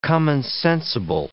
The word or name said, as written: commonsensible